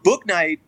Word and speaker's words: Bouknight: Now, Bouknight, is that his last name right? – Did I pronounce that correctly? Yeah. So he was projected possibly to go to the Warriors.